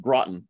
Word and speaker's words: Groton: So first, it's pronounced Groton, but only people who live here know that. So that's how I can always tell if someone's local or not.